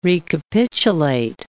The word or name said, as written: recapitulate